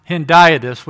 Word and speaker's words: hendiadys: And the word is pronounced hendiadys, which is a fancy word.